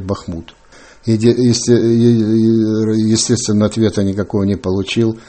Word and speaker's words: Бахмут: Я знаю, у вас є поезія, за допомогою якої ви боретеся за правильний наголос в назві нашого міста. – Ну, борьба – это сложно, громко сказано. Но, по крайней мере, вот мои киевские друзья, они уже все безошибочно говорят «Бахмут».